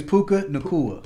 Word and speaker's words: Puka Nacua: His name is Puka Nacua. – Puka Nacua.